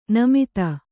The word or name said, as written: ನಿಮ್ಮುತು